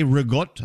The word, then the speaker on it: ricotta: Ricotta. Oh, no, they say rigotto. I'm like, what language? – Yeah, rigotto.